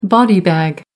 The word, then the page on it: body bag: American English